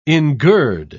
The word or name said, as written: engird